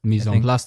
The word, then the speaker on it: mise en place: I practice, and I'm going to obviously pronounce this bad again, mise en place. – Mise en place, that was pretty good.